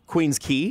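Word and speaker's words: Queen's Quay: And a lot of people don't know how to pronounce Queen's Key, because it's spelled Queen's Quay.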